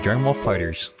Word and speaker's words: Dragon Ball FighterZ: The official pronunciation, by the way, Dragon Ball Fighters. – Are we on? – Yeah. – All right. – Yeah. Namco says Dragon Ball Fighters.